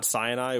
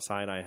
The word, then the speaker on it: Sinai: Center at Mount Sinai. That's how I would pronounce that. – Okay. – It's not Sinai, which is the other way I would say it.